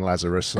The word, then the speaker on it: Lazarus: With a name like Stephen Lazarus, I, have got Nazareth and Lazaru and Nazaru.